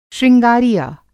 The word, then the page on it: शृंगारिया: Hindi